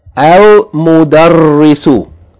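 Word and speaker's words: Al-Mudarrisu (المدرس): The third word should be read as Al-Mudarrisu. Al-Mudarrisu. Moon letter. Yani the lamb is pronounced.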